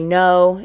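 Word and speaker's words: GNO: And then we jump into the DJ lifestyle, speaking with DJs, DJ No, it's G-N-O, the G is silent.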